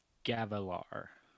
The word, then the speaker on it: Gavilar: Wait, did I say it wrong? – Yep, give him the pronunciation, or give him how you would say it again. – Okay. – Gavilar.